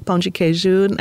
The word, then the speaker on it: pão de queijo: But we are working with a local pão de queijo maker. I'm going to make like a vegetarian feijoada that's going to be served with like a little pão de queijo next to it.